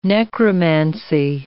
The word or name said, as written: necromancy